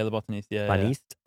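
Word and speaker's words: Le Botaniste: Well, mine is La Botanista. Am I saying it right? – Yeah, La Botanista.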